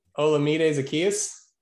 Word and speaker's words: Olamide Zaccheaus: Olamide Zaccheaus. – Olamide Zaccheaus? – Yeah, there you go.